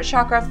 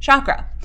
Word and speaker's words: chakra: So a little bit more about the life arena of this chakra. Some people say chakra. I say chakra. Chakra, like it's S-H-A-K, but it's C-H-A-K. So I think really it's pronounced chakra, but I say chakra.